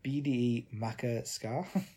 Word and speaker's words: Bde Maka Ska: I won't go through some of the tracks I've got on here, but I chose the new Atmosphere single, BDE Maka Ska. I think that's how you say it. – That's how I'd say it.